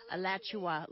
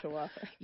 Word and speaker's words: Alachua: Alachua, is that how you say it? – Alachua, like Joshua, but Alachua. – Alachua.